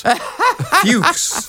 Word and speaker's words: Fux: But how would you pronounce F-U-X? – Fuchs. – Fuchs? – Fuchs.